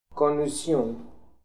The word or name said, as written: Konuzion